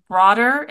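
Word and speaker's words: broddur: They say that the outer coat, which they call broder, if I'm pronouncing it correctly, it's B-R-O-D-D-U-R.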